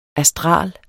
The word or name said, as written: astral